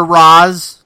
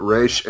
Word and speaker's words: Ra's al Ghul: Or Ra's, depending on how you want to pronounce it. – Did they ever have a definitive pronunciation of that name? Because I've heard it both ways. – I've heard it both in official canon, so I don't know. – I'm going to say the tiebreaker is that Kevin Conroy's Batman voice pronounces it Ra's, and that's it. That's the end of the story.